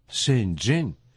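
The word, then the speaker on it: Шэньчжэнь: Первый иероглиф «шэн» произносится в первом доне, в переводе на русский «глубокие». Глубоко. Второй иероглиф «чэн» – это четвертый тон, в переводе «ганава». – «Шэньчжэн» можно дословно перевести как «глубокая канава».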